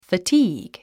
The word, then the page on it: fatigue: fətíːg; ファティーグ